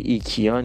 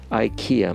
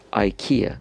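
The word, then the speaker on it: IKEA: اما تلفظ این در زبان انگلیسی ایکیا نیست احتمالا من شنیدم در ایران هم میگفتن ایکیا ولی این در زبان انگلیسی ایکیا میگن بر وزن ایدیا ایکیا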